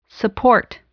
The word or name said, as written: support